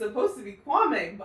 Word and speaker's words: Kwame: First of all, I say his name is Kwame because I feel like that's how it's supposed to be said. – He says Kwame. – Oh, Kwame. Okay.